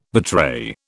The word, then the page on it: betray: British English